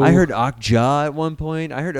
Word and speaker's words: Okja: A viewer and commenter in Korea actually said it's pronounced Okja, but it sounded like Octeo or something, or Okzeo or Okzeo or Okja.